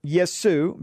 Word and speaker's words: Jesu: French, Jesu, pronounced Yesu, basically, is the Greek form of Aramaic Yeshua.